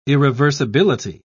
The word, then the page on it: irreversibility: British English